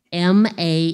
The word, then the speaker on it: Mahalaleel: Before him, in order of appearance, was Adam, then Seth, then Enos, E-N-O-S, Canaan, this guy's name I can't pronounce, M-A-H-A-L-A-L-E-E-L. Go ahead, go for it. It's Hawaiian. It does sound Hawaiian.